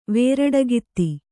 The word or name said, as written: ವೇರಡಗಿತ್ತಿ